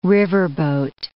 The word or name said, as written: riverboat